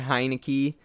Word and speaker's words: Heinicke: I mean, Tyler Heinicke, I believe that's how you say it.